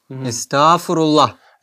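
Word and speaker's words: Estağfurullah: Г это не читается, и ты это произносишь как просто долго А. – Эстафурулла.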